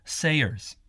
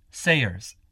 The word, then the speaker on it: Sayers: It's Ralph Sayers. – How do you spell it? – My first name's Ralph. R-A-L-P-H. – And your last name? – Sayers. That's S-A-Y-E-R-S.